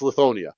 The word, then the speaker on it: Lithonia: Let's say you're installing 10,000 Lithuania. I can never pronounce Lithonia. It's Lithonia. I keep calling it Lithuania, so forgive me for that one.